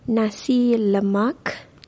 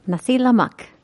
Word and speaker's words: Nasi lemak: Nasi lemak. – Nasi lemak, the Malaysian national breakfast.